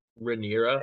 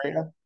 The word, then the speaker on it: Rhaenyra: Rhaenyra. – Rhaenyra.